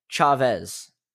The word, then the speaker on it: Chavez: It's South American, in South America, and people, you know, speak Spanish, and so I'm pronouncing things like English. But it's Hugo Chavez, or oh my gosh, I keep doing it, Chavez.